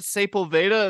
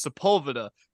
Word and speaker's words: Sepulveda: I'm like, is that Sepulveda? And they're like, you're gonna pronounce it that way? No, it's Sepulveda.